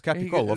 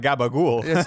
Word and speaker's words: capicola: It's Capicollo. – Yeah, it's Capicola, of course. – Like, you're not fucking around there. – It's not Gabagool.